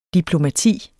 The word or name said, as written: DIPLOMATIETS